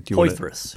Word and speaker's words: Poythress: Poythress.